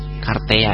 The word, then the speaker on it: Kartea: Bacanya gimana kita? Kartea kah? Karti? Kartea ya, Kartea.